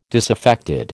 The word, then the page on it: disaffected: American English